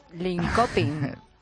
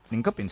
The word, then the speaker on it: Linköping: Linköping. – Linköping, sí.